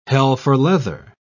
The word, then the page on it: hell-for-leather: British English